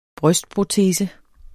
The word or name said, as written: brystprotese